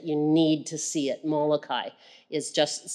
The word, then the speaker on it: Molokai: In 1873, Joseph Damien went to Molokai. Is that right? Molokai. I watched the movie and I still can't get the name right. Molokai? Okay, Molokai in Hawaii.